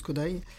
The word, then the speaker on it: Kodály: Kodály, hein, qui s'écrit Kodály, mais qui se prononce Kodály.